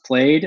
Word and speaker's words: Plaid: But obviously, coming over here, it's pronounced plaid, and people don't think of it as a fabric or pattern.